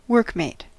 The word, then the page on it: workmate: American English